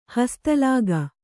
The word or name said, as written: ಹಸ್ತಲಾಗ